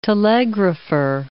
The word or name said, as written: telegrapher